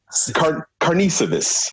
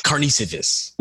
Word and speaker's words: Karnisovas: Yeah, because if I saw in a one-on-one interview Casey Johnson calls him Karnisovas, then I know that he asked that guy how to pronounce his name properly. So that's probably the way to go with it, I feel.